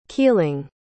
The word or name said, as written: keeling